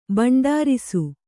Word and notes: ಭಂಡಾರಿಸು: bhaṇḍārisu

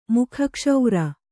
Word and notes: ಮುಖಕ್ಷೌರ: kannada

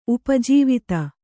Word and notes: ಉಪಜೀವಿತ: upa jīvita

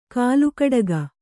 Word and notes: ಕಾಲುಕಡಗ: kannada; kālukaḍaga

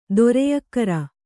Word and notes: ಧೈರ್ಯಗಾರ: kannada

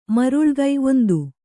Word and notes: ಮರುಳಿಕ್ಕು: maruḷikku

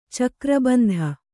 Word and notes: ಚಕ್ರಬಂಧ: cakra bandha

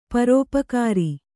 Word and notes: ಪರೋಪಕಾರಿ: parōpakāri

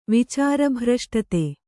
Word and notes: ವಿಚಾರಭ್ರಷ್ಟತೆ: vicāra bhraṣṭate

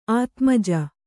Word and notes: ಆತ್ಮಜ: ātmaja

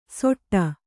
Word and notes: ಸೊಟ್ಟ: soṭṭa; kannada

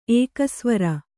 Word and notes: ಏಕಸ್ವರ: ēkasvara

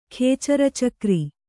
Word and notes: ಖೇಚರಚಕ್ರಿ: Kannada

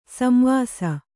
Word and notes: ಸಂವಾಸಿ: samvāsi

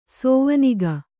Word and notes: ಸೋವನಿಗ: kannada

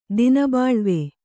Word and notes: ದಿನಬಾಳ್ವೆ: Kannada

